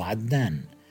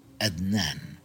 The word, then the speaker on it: عدنان: وأقرب مثال على ذلك يحضرني اسم عدنان، والذي ينطقه الكثير من غير العرب أدنان.